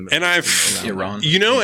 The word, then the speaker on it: Iran: Well, I think how do you pronounce Iran? – I think I say Iran. – It's usually a good rule to do the ah.